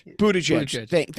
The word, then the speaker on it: Buttigieg: And look, I have always liked, but over the last couple of years have become a really big fan of Poop Buttigieg. And how he's handled, I can't say the name right, so help me out. – It's not Poop Buttigieg. I think it's Buttigieg? – Buttigieg. Thank you.